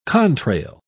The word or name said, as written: contrail